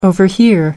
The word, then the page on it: overhear: American English